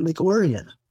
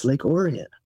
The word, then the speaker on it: Lake Orion: They pronounce it Lake Orion. It's spelled Lake Orion, but they pronounce it Lake Orion for whatever reason. I've always wondered why they don't pronounce it correctly, but it's Lake Orion.